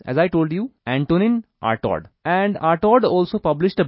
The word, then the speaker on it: Artaud: ARTAUD, आर्टॉड, in the 1930s।